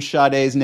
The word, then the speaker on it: Sade: Do you pronounce an R in Sade's name? I've had this debate my entire life. She apparently says it like Sade, and everybody wants to say it like that, and I'm just like, but there's no R in there.